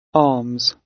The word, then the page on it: arms: British English